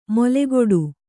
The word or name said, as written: ಮೊಲೆಗೊಡು